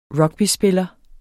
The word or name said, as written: rugbyspiller